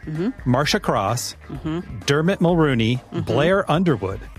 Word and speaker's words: Mulroney: Yeah, it's Mulroney. – Mulroney. – Only Brian Mulroney pronounces it that way.